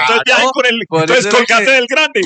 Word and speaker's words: Colgate: Señor, entonces, bueno, no se dice Colgate, se dice Colgate.